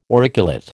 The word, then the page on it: auriculate: American English